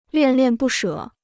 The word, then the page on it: 恋恋不舍: liàn liàn bù shě